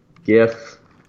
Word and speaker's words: GIF: The inventor of the format, they say because G stands for graphic, GIF should be pronounced with the hard G. But they're saying the guy who came up with it insisted that it's pronounced J-I-F with a soft G, like gelatin or giant.